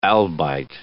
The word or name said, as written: albite